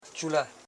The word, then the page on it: chulu: chu,lu